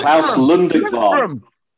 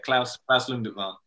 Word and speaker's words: Lundekvam: You didn't say it properly, but I'll give it you anyway because you kind of knew what you were trying to say. But it was Klaus Lundekvam.